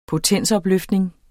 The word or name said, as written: potensopløftning